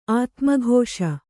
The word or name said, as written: ಆತ್ಮಘೋಷ